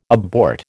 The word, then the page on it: abort: American English